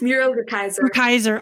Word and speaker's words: Muriel Rukeyser: So when we last talked, you shared with me a powerful quote from Muriel Rukeyser. I'm probably not saying her name right. It's the universe. – Muriel Rukeyser.